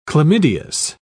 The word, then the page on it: chlamydeous: British English